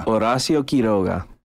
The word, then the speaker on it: Horacio Quiroga: Horacio Quiroga. – That's how you say it if you're not from, Northwest Arkansas.